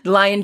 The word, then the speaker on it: Lyon: It's spelled L-Y-O-N. – Okay. – And I mean, I kept thinking of a lion. They were calling him right. – Lyon, but Lyon Burke.